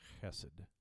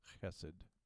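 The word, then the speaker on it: chesed (חסד): One of the most important words in the Hebrew Bible is a word called it's a it's pronounced chesed. It's that it's that German ch sound, chesed.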